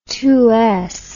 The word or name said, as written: two rowed barley